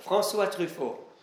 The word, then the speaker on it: Truffaut: François Truffaut.